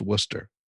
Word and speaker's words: Worcester: And you know, you must know something if you can look at the spelling of the city and know that it's pronounced Worcester.